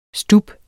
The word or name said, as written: STUBBEN